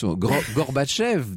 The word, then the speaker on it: Gorbatchev: Alors je prononce là comme je peux à la russe, Gorbatchev, dit-on en France.